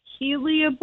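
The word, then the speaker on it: hellebore: So before Christmas, I bought a Lenten rose, a heliobore. Yeah. Is that how you say it? – Yes.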